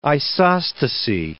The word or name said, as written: isostasy